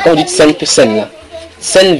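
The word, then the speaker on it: Cène: C'est C-E-N-E. – Sen, c'est comme tu entends sen là, sen, mais tu mets E à la fin.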